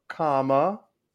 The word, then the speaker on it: Kamala: It is Kamala.